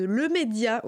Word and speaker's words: Le Média: juste après, un bref message adressé à la rédaction de Le Média, oui, parce que c'est comme avec Les Républicains, on prononce l'article, c'est important.